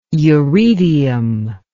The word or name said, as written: uredium